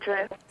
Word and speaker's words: Idris: How do you say his name? – It's Idris. – It is Idris, okay.